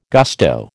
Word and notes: gusto: American English

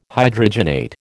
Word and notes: Hydrogenate: American English

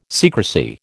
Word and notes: secrecy: American English

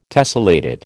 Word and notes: tessellated: American English

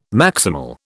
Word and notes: maximal: British English

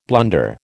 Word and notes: blunder: American English

